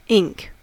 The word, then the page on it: ink: American English